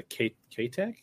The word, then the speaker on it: K-Tech: Best swim bait if the Kitech. Actually, it's the K-Tech. I think it's pronounced K-Tech.